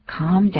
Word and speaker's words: calm: calm down 就是那个 calm，那个 L 不发音，可不要念 calm，念 calm 或是 calm，都不要舌头卷起来，那个 L 就当作没有见到一样。